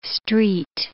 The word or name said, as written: street